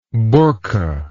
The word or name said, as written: bourkha